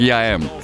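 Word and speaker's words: IAM: Это фанковый ремикс на него и известнейший французский хип-хоп э, музыкант 90-х Яэм. Он пишется как YAM.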